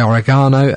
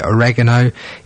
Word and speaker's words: oregano: While that track was on, I've been very reliably informed that, British people say oregano, Americans say oregano, Italians, who you've got to go with, really, say more like oregano. Oregano. Oregano. Oregano. There's many ways of saying it, basically.